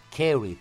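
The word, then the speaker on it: curry: Μου τη λέει ο φίλος ο Δημήτρης από τον Άγιο Νικόλα της Κρήτης, το κάρι είναι για το κοτόπουλο, carry προφέρεται.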